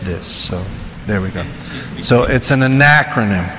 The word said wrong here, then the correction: acronym: So how did I say it right? – No. – I still didn't say it right. Acronym. – Acronym. – Okay. It's an acronym.